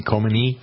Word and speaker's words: Khomeini: Is that how you pronounce his name? Khomeini? – Yeah.